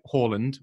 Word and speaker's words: Haaland: And they, you know, maybe Erling Haaland, which is how we now have to pronounce it, can't explain like why he made that run.